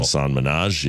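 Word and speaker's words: Hasan Minhaj: Hasan Minhaji, yeah. – Is that how you say his name? – Yeah.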